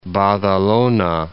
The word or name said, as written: Badalona